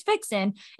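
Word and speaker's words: Vicsen: And so many people, when I say that to them about what Vixen is, because they don't even know, they're like, how do I pronounce it? I'm like, it's Vixen. It's just V-I-C for Victoria and S-E-N for sensual.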